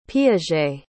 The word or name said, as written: piaget